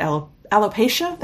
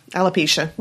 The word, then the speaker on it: alopecia: But she's got alopecia, that's how you say it. – Yeah, alopecia.